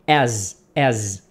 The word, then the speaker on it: as: Agora, muito importante, a pronúncia desse as é com som de Z. Porque se você falar com som de S no final, aí você vai estar falando a palavra A-S-S, que quer dizer o seu traseiro. Tá bom? Então, preste atenção. Este A-S com um S só, a pronúncia é com Z.